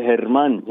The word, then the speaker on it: Herman: German, am I pronouncing your name right? – That's right, Herman.